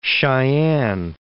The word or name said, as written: Sheyenne